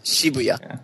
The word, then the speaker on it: Shibuya: But I believe the quote-unquote proper pronunciation is Shibuya. Yeah.